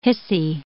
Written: hissy

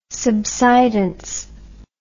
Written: subsidence

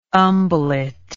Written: umbellet

